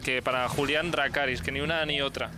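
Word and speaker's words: Dracarys: Eh, Dracaris, ¿no? – Se dice así, ¿verdad? Eso, Dracaris.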